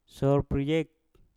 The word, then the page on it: sour priyek: mpur